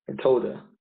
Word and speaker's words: Toda: And how do you pronounce that? Toda? – Toda. – Toda. You actually say Toda.